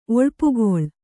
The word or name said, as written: ಒಳ್ಪುಗೊಳ್